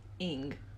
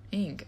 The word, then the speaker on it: Ng: Ing. – Ing.